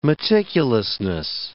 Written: meticulousness

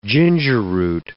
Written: gingerroot

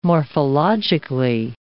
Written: morphologically

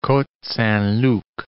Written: Cote-Saint-Luc

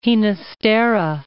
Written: Ginastera

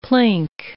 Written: plink

